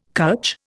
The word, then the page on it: cutch: Indian